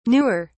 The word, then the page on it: newer: British English